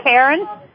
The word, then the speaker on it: Karin: Is it Karen? – Karen, yes. – Okay. – K-A-R-I-N.